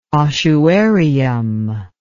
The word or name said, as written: ossuarium